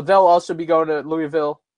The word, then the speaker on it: Louisville: It'll be in Louisville, Kentucky, or Louisville, as I learned when we were there how it's actually pronounced, Louisville.